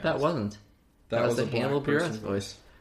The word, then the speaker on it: Hannibal Buress: Also, it is pronounced Hannibal Buress.